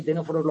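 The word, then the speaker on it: ctenóforos: Entonces, fijaos, hemos pasado por una primera red nerviosa, acordado, unidarios y tenóforos los primeros, la C, que no se pronuncia donde se expresa.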